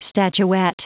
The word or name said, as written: statuette